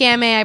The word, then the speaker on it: Gamay: It's gamay. I pronounced it wrong because I'm dyslexic and this is what happens to my life.